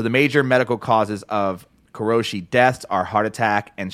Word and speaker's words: karoshi: So, like, the word that I'm talking about is, I believe it's pronounced karoshi.